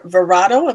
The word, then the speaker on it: Verado: Your V12 600 horsepower Verado.